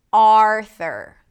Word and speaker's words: Arthur: There is no U sound, even though it's spelled with a U, there is no U, Arthur. It's called a schwa. And you can learn more about it if you click the link in the show notes, in the description. Arthur. Primary stress is on the first syllable.